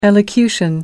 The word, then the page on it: elocution: American English